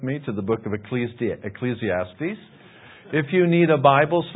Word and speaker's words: Ecclesiastes: There's no C on the end. It's not Ecclesiastics. I hear a lot of people say, oh, we're in Ecclesiastics. I don't see a C on there. It's Ecclesiastes, okay?